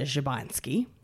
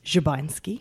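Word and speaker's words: Szubanski: Clear citizen is Magda Szybański, which is the correct pronunciation. – Do it again, please. – Szybański.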